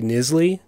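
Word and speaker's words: Knisley: Nisley. It's K-N-I-S-L-E-Y, so I think I'm saying that right.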